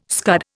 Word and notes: scud: Indian

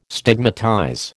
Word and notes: stigmatize: American English

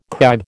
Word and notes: kibe: American English

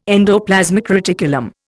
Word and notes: endoplasmic reticulum: Indian

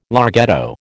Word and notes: larghetto: American English

